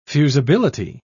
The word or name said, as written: fusibility